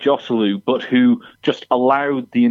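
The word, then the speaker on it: Joselu: I think we should have some sort of system where if you pronounce the J in Hosselu, you have to put a pound in a ha, as in a jar. I think we have to stick to this. – It's Hosselu.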